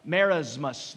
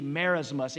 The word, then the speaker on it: merismus: There's a literary word, let's put that up there, it starts with an M, it's kind of a fancy word, it's merismus, that's a S is pronounced as a Z, merismus.